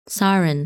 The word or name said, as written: Sarin